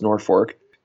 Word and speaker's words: Norfolk: It's spelled like Norfolk, Virginia, but it's pronounced Norfolk.